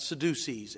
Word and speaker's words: Sadducees: And what they did was, it's spelled S-A-D-D-U-C-E-E-S. And that's not Sadducees as opposed to seducers. That is Sadducees.